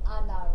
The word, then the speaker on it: Hannah: my English name is like Hannah, but they French don't pronounce H, so they call me Anna, right?